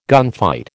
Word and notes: gunfight: American English

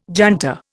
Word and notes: junta: Indian

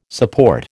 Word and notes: support: American English

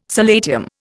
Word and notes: solatium: Indian

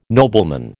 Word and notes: nobleman: American English

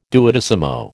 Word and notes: duodecimo: American English

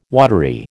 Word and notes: watery: American English

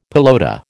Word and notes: pelota: American English

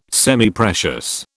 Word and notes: Semiprecious: British English

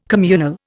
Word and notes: communal: Indian

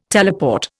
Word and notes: teleport: Indian

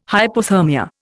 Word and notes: hypothermia: Indian

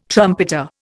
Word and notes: trumpeter: Indian

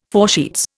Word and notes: foresheets: Indian

